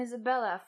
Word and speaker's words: Isabella: I always say, like, I'm Isabella from Venezuela, and my name rhymes with my country.